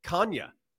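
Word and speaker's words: Canha: Mark Hanna was on ESPN, mic'd up, and he actually said, yeah, the actual pronunciation is Kanya.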